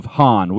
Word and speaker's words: HAAN: So obviously, Chuck is the lead singer of HAAN. We are pronouncing that correct. Correct? – You are. Yes.